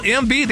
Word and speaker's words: Embiid: Joel Embiid.